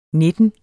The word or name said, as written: nitten